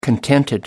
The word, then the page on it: contented: American English